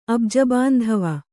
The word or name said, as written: ಅಬ್ಜಬಾಂಧವ